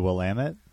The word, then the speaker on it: Willamette: The Willamette? – Okay.